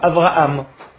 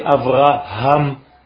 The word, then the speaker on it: Avraham: Et donc c'est faire attention, surtout quand on vient de France ou d'ailleurs, de prononcer la lettre He, qui est difficile pour les francophones, qui prononcent généralement le He comme un Aleph, Abraham. Ce n'est pas Abraham, c'est Avraham.